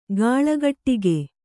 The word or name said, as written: ಕಳ್ಗುಡಿಕ